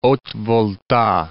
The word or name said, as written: Haute-Volta